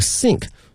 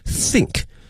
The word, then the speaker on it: sink: in their sink，水槽用的一个 sink，同学们注意啊，它的发音呢和我们说思考，我们想某件这个事情呢有一些接近，但是呢它的这个舌位是不一样的哈。这个思考或想某件事情的话是 think，是这个 think，需要一个咬住上下齿的这么一个发音，而这个是一个 sink，不需要咬的一个轻音哈。